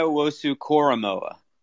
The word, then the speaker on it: Owusu-Koramoah: And I always butcher his name. What is it? Karamoah? – Yeah. Oh, you're getting closer, man. You're getting it's Jeremiah Owusu-Koramoah.